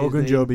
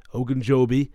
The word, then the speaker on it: Ogunjobi: They have David Ojanubi, whatever. How do you say Ogunjobi. – His name? Ogunjobi. – Larry Ogunjobi.